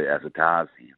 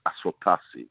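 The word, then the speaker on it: Asotasi: Like, you know, they used to call him Roy Azatazi, for example. You know, it's Aswatasi, you know.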